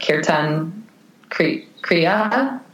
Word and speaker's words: kirtan kriya: So the meditation that I do currently, I do it every day, the 11 minute kirtan kriya.